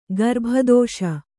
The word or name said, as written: ಗರ್ಭದೋಷ